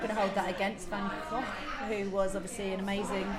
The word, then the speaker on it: van Gogh: Next on the list, let's go with Vincent van Gogh, as he is pronounced, because I speak fluent Nederlands.